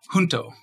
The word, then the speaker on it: junto: Junto just means together. So Junto means together in Spanish, right? – Yeah.